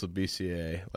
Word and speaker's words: Labissiere: I'm pretty sure it's Labissiere, like Scalabissiere.